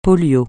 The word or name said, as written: polio